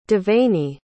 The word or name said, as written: Devaney